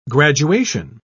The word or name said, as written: graduation